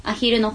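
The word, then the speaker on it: @HILL: はい、 4 月の 13 日土曜日大阪西心斎橋ショベル、そして 5 月の 25 日土曜日愛知柴田、こちら名古屋ですね、アヒルの方でライブがあります。 – これアットヒルって書いてアヒルって読むんですね。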